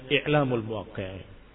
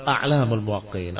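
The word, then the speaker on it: A'lām al-Muwaqqi'īn: Dan itu yang lebih rajih daripada membacanya I'lamul Muwaqqi'in. Tapi yang benar A'lamul Muwaqqi'in. Apa bedanya? A'lam tokoh-tokoh ulama. Tapi kalau I'lam pemberitahuan, pengkhabaran.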